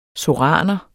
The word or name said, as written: soraner